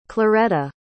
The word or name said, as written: clareta